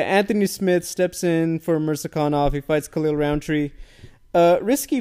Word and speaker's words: Mirzakhanov: Oh my God, I always mess it up. Mirzakhanov. Did I say it correctly? – I think so. – Oh my God, I always feel bad. Yes, Mirzakhanov.